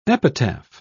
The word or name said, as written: epitaph